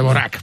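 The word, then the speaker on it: Dvořák: que lo has pronunciado de una forma sí, bueno, es que cada uno lo pronuncia un poco de Boyac, pero bueno, es de Borac, para quien lo quiera buscar, de Borac.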